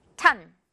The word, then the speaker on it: ton: O 하나 있지만 이것도 어 발음 납니다. ton 이렇게 발음이 나요.